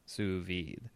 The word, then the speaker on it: sous vide: It's spelled S-O-U-S space or dash V-I-D-E. – Mm-hmm. – Sous vide, and you have to put your pinky in there when you say it.